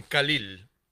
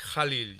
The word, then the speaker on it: Khalil: Algunos le dicen Khalil, porque se escribe con K, pero la KH se pronuncia J, es Jalil Gibran.